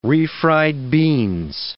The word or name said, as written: refried beans